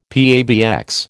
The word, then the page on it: PABX: American English